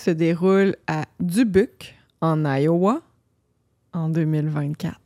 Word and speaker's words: Dubuque: Dubuque. – Yeah. Mais vu que c'est fondé par un Français canadien, la prononciation devrait être Dubuque.